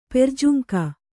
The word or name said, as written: ಪೆರ್ಜುಂಕ